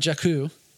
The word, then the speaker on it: Jakku: She spent a lot of time on Jakku.